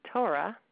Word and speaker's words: Torah: pronounced clearly so that you can hear the or in it. One of the things I do for new Balei Tshuva is write down Torah for them, T-O-E-R-A-H, so they learn how to pronounce it like a not Balei Tshuva. It shouldn't be Torah, Torah.